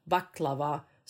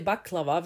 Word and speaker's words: baklava: Jag säger baklava, vissa säger baklava och vissa säger baklava. Alltså förstår du att det finns beroende på var du kommer ifrån. Så om någon nu säger så här, när de lyssnar på oss, varför uttalar hon, varför har hon betoningen där, så är det ju för att jag betonade på makedonskt vis.